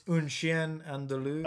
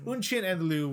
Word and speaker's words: Un Chien Andalou: this it's it's Un Chien Andalou. Okay, Un Chien Andalou, which it, is an Andalou boy, whatever.